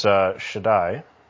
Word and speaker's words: Shaddai: It's Shaddai.